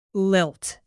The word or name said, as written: lilt